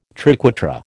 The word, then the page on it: triquetra: American English